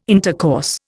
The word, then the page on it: intercourse: Indian